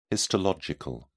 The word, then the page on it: histological: British English